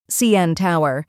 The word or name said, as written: CN Tower